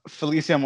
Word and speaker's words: Felicia: Yes, I typically, it's Felicia, but I don't mind the other way.